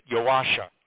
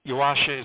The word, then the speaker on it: Yuasa: Yeah, let me correct your pronunciation a bit. Yuasa. Now, her real name was Joanna, or Joanne, but nobody called her that. Yuasa is the diminutive form. It's like Joni for Joanne, mm-hmm. So it's Yuasa.